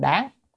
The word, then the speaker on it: đáng: Đương ở chỗ này không bao đầu là đương, phải đáng, âm đáng mới đúng.